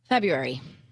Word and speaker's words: February: February. – Well, that's a hybrid. That was like a February? Kind of a gloss over. Do you say February or February? – I never pronounce the R, February.